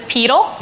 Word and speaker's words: pedal: This adjective, referring to the foot, is properly pronounced to rhyme with needle. – What is pedal? – Yes.